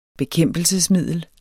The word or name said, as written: bekæmpelsesmiddel